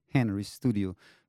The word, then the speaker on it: Henristudio: I think the last part is easy because it's similar to American name, like Henristudio.